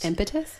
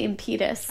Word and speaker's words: impetus: That's the word Mary told me I mispronounced. Impetus? Yeah, I used to say impetus.